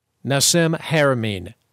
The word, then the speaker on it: Nassim Haramein: Nassim Haramein.